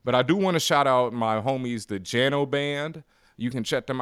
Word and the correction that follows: Jaano: I think it's actually pronounced like Yano Band, but it's spelled J A A N O Band.